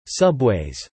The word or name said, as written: subways